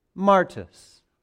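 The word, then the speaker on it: martus (μάρτυς): This is pronounced martus. What does that sound like? martyr.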